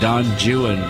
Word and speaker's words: Don Juan: The poem won't work unless all the names are mispronounced like that. Don Juan, because it rhymes with new one, true one, yeah.